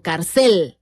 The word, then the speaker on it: cárcel: Ahora sí que, ¿cuál es la sílaba fuerte? ¿Cárcel o cárcel? Ah, pues cárcel, ¿verdad? Es una palabra grave. Su sílaba fuerte o tónica es la penúltima sílaba.